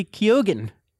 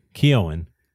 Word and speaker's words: Keoghan: Keoghan. – According to Barry, he says, oh, I pronounced the G.